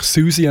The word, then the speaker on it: Siouxsie: Und die nächste Band heisst eben geschrieben, Siouxsie and the Banshees. Und wir sagen natürlich Susie and the Banshees.